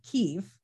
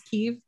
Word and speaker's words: Kyiv: Slava grew up in a Ukrainian-speaking West, studied in a Russian-speaking Kyiv. That's how they say it, right? In Ukraine, yes. Is Kyiv.